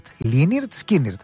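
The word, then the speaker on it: Lynyrd Skynyrd: αν θα ακούσουμε κάτι ακόμη από Λίναρτ Σκίναρτ, προηγουμένως έκανε καλαμπούρι ο Μιχάλης, το έκανε. Πώς ακριβώς προσφέρονται εκεί στο Νότο? Λίναρτ Σκίναρτ, έτσι προφέρονται.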